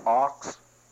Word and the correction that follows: Ochs: I might be pronouncing his name wrong. O-C-H-S. Oaks? Good, thank you. It's Oaks.